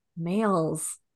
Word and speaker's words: Mayles: Mayles, like male, but it's spelled with a Y.